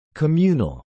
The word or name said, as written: communal